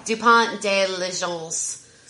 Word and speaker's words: Dupont de Ligonnès: DuPont de Ligonnès.